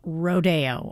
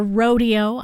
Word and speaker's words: Rodeo: Buckaroo Holiday from Rodeo, or Rodeo. I know it's pronounced both ways. Aaron Copland wrote the music and apparently pronounced it both ways himself.